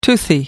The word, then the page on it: toothy: American English